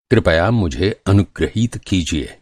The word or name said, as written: अनुगृहीत